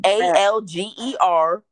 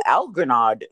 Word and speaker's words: Algernon: No, it's Algernon. I'm not sure how else I'm supposed to pronounce it. Algernon? – Yeah, Algernon. Yep, Algernon. It's literally spelt how I'm saying it.